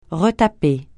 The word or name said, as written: retaper